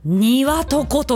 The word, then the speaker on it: 接骨木: 接着剤の接に骨、ボーンの骨にトゥリーの木、接着剤の接に骨に木で何と読むでしょう。にわとこと読むらしいです。